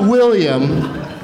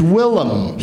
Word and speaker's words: Willem: It's not William, it's Willem.